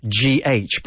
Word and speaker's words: cough: Don't forget, cough is written COUGH, but we pronounce it cough, right?